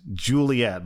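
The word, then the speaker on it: Ghiuliyette: Okay, they decided to name their daughter, pronounced Juliet, but I gotta spell this, guys. Get ready for this. The Jew of Juliet is spelled G-H-I-U. The Lee is spelled L-I, and then the Et is spelled Y-E-T-T-E. And her middle name is Maria, M-A-H-R-I-Y-A.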